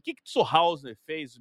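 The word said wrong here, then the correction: zur Hausen: Me diz esse nome, me faz pronunciar certo esse nome, que eu vou pegar o nome. Então, aqui. o Zurhausen é o segunda personalidade fantástica e fundamental da nossa aula de hoje.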